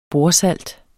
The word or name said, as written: bordsalt